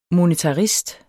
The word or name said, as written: monetarist